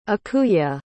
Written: ikuye

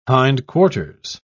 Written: hindquarters

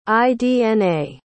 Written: idna

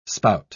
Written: spout

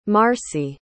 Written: marci

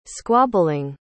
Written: squabbling

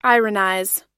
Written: ironize

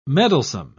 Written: meddlesome

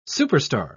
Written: superstar

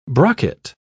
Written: brocket

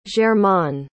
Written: germain

